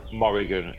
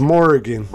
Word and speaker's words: Morrigan: Morrigan. M-O-R-R. – Oh, yeah. Okay, so it's Morrigan. It sounds like you're saying Mulligan. – Yeah. It's probably my accent, mate.